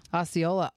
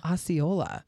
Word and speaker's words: Osceola: Is that how you say it? Osceola, Iowa? – Osceola. – Oh. – Look at that. – Butchered it. How do you say it? – You got owned. – Osceola.